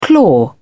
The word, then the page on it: claw: British English